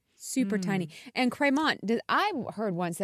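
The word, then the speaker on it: Crémant: The word Cremant, which is spelled C-R-E-M-A-N-T.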